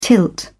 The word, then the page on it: tilt: British English